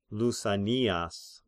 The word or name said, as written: Lysanias